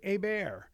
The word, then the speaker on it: Hebert: I remember the first time I met him because his last name is spelled H-E-R-B-E-R-T. So I'm like, oh, Dr. Corey Herbert. And he's like, Hebert.